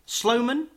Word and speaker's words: Sloman: Sloman, is that the pronunciation? – That's the one, yeah.